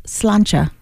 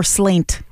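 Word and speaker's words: Sláinte: I remember I was at a bar one time called Slancha. – Mm-hmm. – Or slaint, as it's spelled. Don't ever say that.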